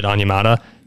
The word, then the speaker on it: Onyemata: They brought in Calais Campbell and, like you said, David Onyemata, both coming in on the interior. Did I pronounce that wildly off? – No, you're good.